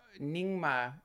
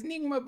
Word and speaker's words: Nyingma: suddenly Nyingma, is that how you pronounce it? – Yeah, yeah.